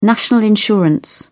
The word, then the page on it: national insurance: British English